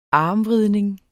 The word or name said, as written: armvridning